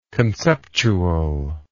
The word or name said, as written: conceptually